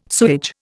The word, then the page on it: sewage: Indian